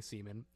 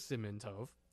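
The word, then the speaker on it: Simon Tov (סימן טוב): And I was like, no, you absolutely should not be singing semen. It is Simon Tov.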